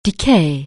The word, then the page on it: decay: anglais